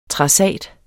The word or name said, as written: trassat